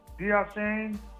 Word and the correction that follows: Achane: I can't say his name. – Achane. – Achane, yeah, okay.